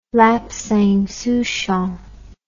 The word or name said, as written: lapsang souchong